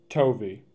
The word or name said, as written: Tovey